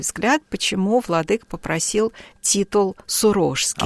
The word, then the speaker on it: Сурожский: Дело в том, что сам владыка произносил свой титул как «Сурожский». И город, в честь которого названа соответствующая епархия, Сурож, и поэтому он делал ударение вот таким образом, Сурожский.